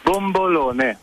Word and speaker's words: bombolone: Donc, et comment on le prononce Aidez-nous à le prononcer, bombolona. – Bombolone. – Bombolone.